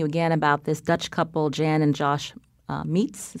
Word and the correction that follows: Smeets: is that how you say it? – Smeets, yeah. – Smeets.